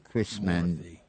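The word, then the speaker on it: Krishnamoorthi: K-R-I-S-H, M-A-N, O-O-R-H-I, Krishnamoorthy.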